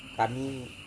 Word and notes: kami: Serewen

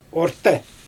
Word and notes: orte: Xyzyl

